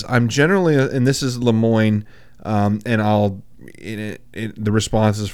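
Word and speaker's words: Lemoine: I'm saying Lemoyne. I think it's Lemoyne. – Yeah, that should be right.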